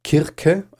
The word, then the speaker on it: Kirke: Also die Zauberin heißt im Griechischen tatsächlich Kirke, also mit K ausgesprochen.